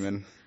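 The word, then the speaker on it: Paimon: And then end the movie with a Hail Pomone. – Hail Payman. – Or Payman. Sorry, Payman. I'm saying it wrong. – Pomone. – Pomone. – Payman. Sounds like a cheese. – It is Payman.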